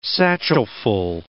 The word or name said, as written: satchelful